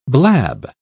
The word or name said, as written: blab